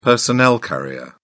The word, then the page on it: personnel carrier: British English